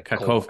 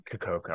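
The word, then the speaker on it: Kakhovka: The Kachkova Dam. Did I say that right? – Yeah. Yeah. – Okay. – Kachkovka cool. Dam. – Kachkovka. I was close.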